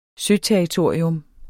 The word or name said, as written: søterritorium